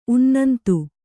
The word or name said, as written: ಉನ್ನಂತು